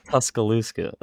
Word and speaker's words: Tuscaloosa: It's definitely an awesome area that they have there, right next to Tuscaloosa and did I say that right? – Tuscaloosa.